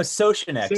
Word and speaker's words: Soucinek: And then, so Sosnick. So I always say it's Sosh and then a neck.